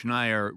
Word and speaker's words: Schneier: Bruce Schneier rhymes with, what did you say? – Frequent flyer. – There you go.